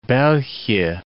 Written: Belgie

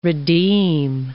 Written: redeem

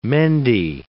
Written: Mende

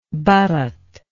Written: Bharat

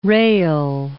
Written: rail

